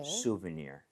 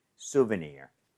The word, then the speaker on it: souvenir: NIR, but 아. – near처럼 발음하시면 돼요. – 네. – souvenir 이런 느낌. 자, 발음 한번 가보도록 하겠습니다. – 네. – souvenir, souvenir.